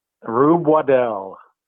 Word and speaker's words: Waddell: and Rube Waddle. I believe is how you say his name. – Or is it Waddell? – I don't know. – Waddell. – How do you pronounce Rube's last name? – Rube Waddell.